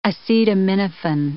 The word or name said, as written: acetaminophen